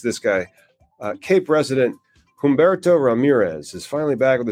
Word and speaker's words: Humberto: So the H is silent. It's Humberto.